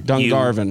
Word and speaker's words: Dungarvan: Conan, how do you say this? Dungarvan? – Dungarvan. You barbarian.